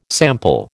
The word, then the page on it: sample: American English